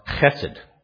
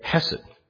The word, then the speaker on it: hesed: And you'll notice it's spelled there on the outline with the K in front, and that's because if you want to be really Jewish, you have to use a guttural sound at the beginning, you know, hesed. But I don't want to spit on anybody, so I'm just going to say hesed, okay?